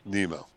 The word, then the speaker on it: Nimmo: You pronounce his name Nemo or Nimmo? Where do you come in on that? – I'll bet you $30 his name is pronounced Nemo. – Like Finding Nemo? – I don't know.